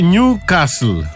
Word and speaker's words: Newcastle: Προφέρεται Νιου Κάστλ. – Δεν έχει τάφ, έχει δίκιο. – Ναι, έχει δίκιο, Νιου Κάστλ. Άνευ του.